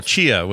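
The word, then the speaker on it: Tchia: Chia, which is spelled with a T. T-C-H-I-A.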